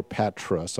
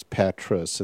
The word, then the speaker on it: Patras: They joined me in Patra, and we sailed the first day, early morning, from Patra, Greece, or Patras. I'm not sure how you pronounce it. I always say Patra, but it's Patras.